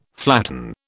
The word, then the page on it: flatten: British English